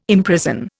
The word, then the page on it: imprison: Indian